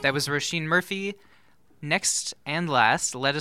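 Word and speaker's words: Róisín: So this is Roisin Murphy. Oh, and by the way, if you're listening and you're figuring out how to Google it, her name is spelled O-R-I-S-I-N. – Yeah. – That's Irish Roisin.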